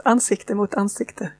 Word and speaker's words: Ansikte mot ansikte: or I'll try and pronounce this, Ansikte mot Ansikte. Is that roughly right? – Very good. It's Ansikte mot Ansikte, yes.